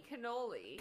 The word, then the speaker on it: cannoli: He introduces her to cannelloni, but I mean cannoli. – Cannoli. – Yes, not cannelloni. – I always, yeah, yeah. It's so confusing. – We're just adding in an extra syllable, a couple more, you know, e's and l's, and yeah, we're just kind of yeah, but. It's not that.